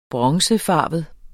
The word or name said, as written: bronzefarvet